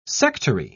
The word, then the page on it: sectary: British English